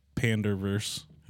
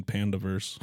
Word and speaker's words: Panderverse: South Park joining the panderverse. I gotta make sure I say that right. I kept saying pandaverse.